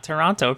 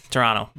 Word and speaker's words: Toronto: Say it like a not Toronto person said. If you're from Toronto, you don't say the T.